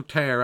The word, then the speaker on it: tear: When I use the word tear, I'm aware that there are two ways of pronouncing that word, T-E-A-R. There is tear as in rip, but there's also tear as in tear that drops from our eyes.